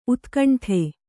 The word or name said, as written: ಊದುಗಂಡಿ